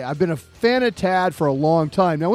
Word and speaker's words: TAD: This show is TAD, which rhymes with sad.